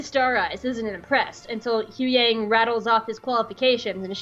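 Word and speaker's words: Huyang: I know his name! It's Hu Yang! Hu Yang, it's two syllables.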